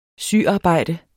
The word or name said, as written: syarbejde